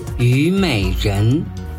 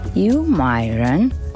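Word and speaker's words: Юймэйрэн (虞美人): Юймэйрэн. – Ю – вторым тоном. Май – третьим тоном. Рэн – вторым тоном. Вместе получается Юймэйрэн – мак.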